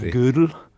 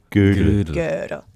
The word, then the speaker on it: Gödel: I hear it often pronounced, well, I don't speak German, but mathematicians often say it like the woman's piece of clothing, you know, from the old days. But something like Gödel. – Gödel. – So there's a kind of sound in the middle.